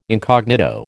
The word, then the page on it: incognito: American English